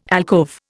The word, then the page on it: alcove: Indian